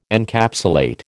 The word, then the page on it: Encapsulate: American English